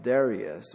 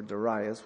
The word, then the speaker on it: Darius: and was the son of Darius, or Darius, whichever you want to pronounce it, the first.